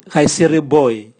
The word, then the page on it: gaisiroboe: Raga